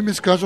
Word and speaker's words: Mescage: Est-ce que je le prononce bien? – Oui, Mescage, en breton, avec l'accent de Brest, c'est Mescage.